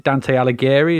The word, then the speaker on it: Dante Alighieri: So I don't know if you pronounce it Dante Alighieri. Is that how you pronounce it? That's right. Correct.